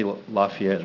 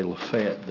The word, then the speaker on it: Lafayette: We don't say Lafayette, oh, okay. – We say Lafayette there. – Oh, Lafayette. – But that is the way it's spelled.